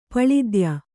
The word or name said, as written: ಪಳಿದ್ಯ